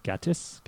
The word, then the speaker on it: Gatiss: So Mark Gatiss, I hope I'm pronouncing that right. Gatiss. – Yes.